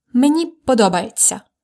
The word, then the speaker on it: мені подобається: Мені подобається. I like. Let's try to drill the pronunciation. Me ні. По, до, ба, є, ця. Мені подобається. Мені подобається.